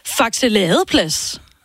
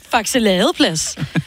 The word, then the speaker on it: Faxe Ladeplads: Jeg har lige været i Superbrugsen i, og nu ved jeg, hvordan man udtaler det, for jeg har gået på efterskole med en, der boede der, i Faxe Ladeplads. Det er sådan, man udtaler.